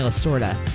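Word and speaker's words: Lasorda: He's reportedly in talks to play the legendary Dodgers manager Tommy La Russa in a new movie called Tommy and Me. Tommy La Sorda. I said that wrong.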